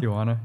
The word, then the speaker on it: Tijuana: Did I pronounce that right? Tijuana. – Tijuana. – Yeah. Tijuana. And like drink.